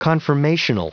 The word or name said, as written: confirmational